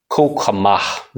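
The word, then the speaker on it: kauchema: If you want to study the Greek word for some reason, it's K-A-U-C-H-E-M-A. So again, that's K-A-U-C-H-E-M-A. Kokamah.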